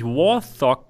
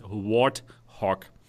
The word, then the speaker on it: Warthog: Ich habe es immer falsch ausgesprochen, auch in meinem englischen Video habe ich nämlich Warthog gesagt. Ich dachte, das TH wird TH ausgesprochen, aber mir wurde gesagt, nein, es heißt Warthog.